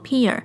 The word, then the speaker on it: Pierre: 节目的最后呢，特别感谢一位听众 P I E R R E，应该是念作 Peer，谢谢你的小额赞助，我已经收到了你的心意，非常的感谢你愿意赞助小爱心这个节目。